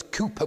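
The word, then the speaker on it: Cowper: William, that's Cooper with a W, but it's pronounced Cooper, I understand, a Scotsman, and he's one of his many hymns.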